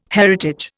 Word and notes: heritage: Indian

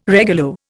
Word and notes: regulo: Indian

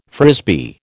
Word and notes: Frisbee: American English

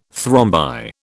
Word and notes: thrombi: British English

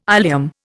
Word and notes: ileum: Indian

